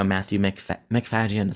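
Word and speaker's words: Matthew Macfadyen: I do think like Sarah Snook deserved, you know, Matthew McFadyen.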